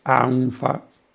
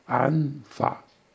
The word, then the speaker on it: anfa: Ma il milanese accentua ancora questa sensazione in mancanza di respiro, con un lemma straordinariamente efficace di anfa, e lo si pronuncia tal quale, ma quasi con una sospensione di respiro tra le due sillabe, anfa, anfa, anfa, anfa, creando così una respirazione affannosa.